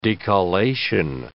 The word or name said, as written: decollation